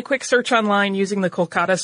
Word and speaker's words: Kolkata: At that point, the Indian government renamed the city Kolkata to more closely reflect the Bengali pronunciation.